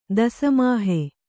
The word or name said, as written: ದಸಮಾಹೆ